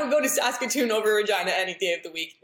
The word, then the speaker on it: Regina: Yes, Regina, the city that rhymes with fun.